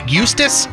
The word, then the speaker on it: Eustis: Today's Headline Hooge comes to us from, I guess you pronounce this, Eustis? E-U-S-T-I-S.